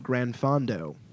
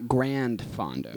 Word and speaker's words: granfondo: Granfondo. What is a granfondo for us? – Can you spell it? – GRANFONDO. – So it's not grandfondo. – Yeah, it's granfondo.